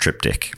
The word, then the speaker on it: triptych: And for new listeners, we do now know that it's pronounced triptych. – That's right. – It's a joke that we've always been in on. – We've been in on it. My art teacher in high school just said it wrong.